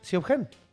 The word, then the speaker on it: Siobhan: Si yo te escribo un nombre que se escribe S-I-O, Sio, B-H-A-N, Siobhan. ¿Cómo lo pronuncias? Siobhan. – Sí, sí. – ¿Sabes cómo se pronuncia? – Siobhan. – Sí. Siobhan. – Como franchute. – Siobhan, güey. ¡Neta, güey! – Como franchute. – Todo, sí, güey. Suena como franchute, pero se escribe Siobhan, güey.